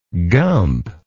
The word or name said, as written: gump